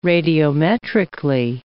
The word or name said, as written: radiometrically